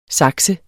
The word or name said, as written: saks